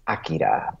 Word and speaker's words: Akira: Or, I mean, to say it correctly, it's Akira. But the first version of it, the streamlined dub, it was Akira.